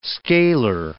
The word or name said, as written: scalar